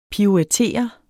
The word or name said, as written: PIRUETTER